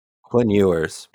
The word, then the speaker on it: Quinn Ewers: Quinn Ewers.